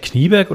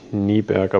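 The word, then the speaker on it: Kniberg: Wird er eigentlich Knieberg oder Nieberg ausgesprochen? – Äh, ich glaube eigentlich Nieberg, aber Knieberg ja geschrieben.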